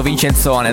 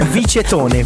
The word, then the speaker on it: Vicetone: Avevamo dubbi su come si pronunciasse Vincent One, l'abbiamo chiamato Vincenzone, dai. – Vincenzone, secondo me è un bel Vicetone.